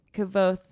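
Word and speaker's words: Kvothe: He says it's actually Kavoth, the E is silent.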